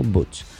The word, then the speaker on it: Buch: Eh, y según explica Mikel Buch, o Butch, Ah, mejor dicho, soy catalán y no lo pronuncie bien yo, Miquel Butch, eh, consejero de Interior de la Generalitat de Cataluña.